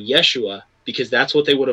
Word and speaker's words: Yeshua: In Hebrew, it would have been pronounced Yeshua.